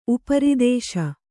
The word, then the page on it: ಉಪರೋಧಿಸು: uparōdhisu